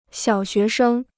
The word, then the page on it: 小学生: xiǎo xué shēng